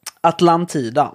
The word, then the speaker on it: Atlantida: Det, han har skrivit någonstans hur det ska uttalas, jag måste... Nej men liksom, ni, ni spelar FN-soldater. Punkt. Atlantida, ja. Uh, ska det vara.